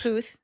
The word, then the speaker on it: Puth: Is it Puth or Puth? – Puth, uh-huh.